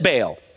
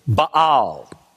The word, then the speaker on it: Baal: Friends, from my studies, I think I have learned that the correct pronunciation of the name of that pagan god is Baal. But for some reason, very early in my Christian life, it got ingrained in me that his name is Baal.